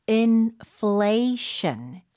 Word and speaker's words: inflation: 인프 소리가 아니에요. F 소리입니다. Inflation. Inflation. – Inflation. Right. F는 여러분들 아랫입술 위에 윗니를 살짝 올려놓고 바람을 뿜는다라고 생각을 하셔야 됩니다.